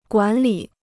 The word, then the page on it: 管理: guǎn lǐ